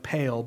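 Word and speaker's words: pale (πάλη): P-A-L-E. It's spelled like pale, but it's pronounced pale in Greek.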